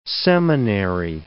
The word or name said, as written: seminary